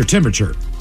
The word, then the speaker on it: temperature: Your temperature. – Temperature. Got to make sure to get that other R in there.